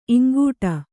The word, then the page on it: ಇಂಗೂಟ: kannada